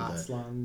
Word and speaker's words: Aslan: Aslan.